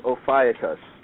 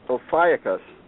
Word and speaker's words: Ophiuchus: For people that ask, I did hear the pronunciation of the new sign is Ophiuchus. – What? Huh? – There's a new in this change. There's also a new sign. There's a 13th sign, and it's Ophiuchus. Ophiuchus.